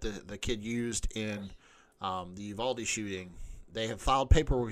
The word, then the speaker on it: Uvalde: I'm saying that wrong. – Is it Uvalde? – Uvalde, and I'm honestly asking because I keep fucking it up. – Yeah, Uvalde, the